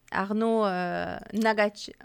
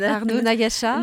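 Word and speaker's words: Arnaud Ngatcha: Arnaud Nagacha, j'ai prononcé très mal son nom. – Arnaud Nagacha.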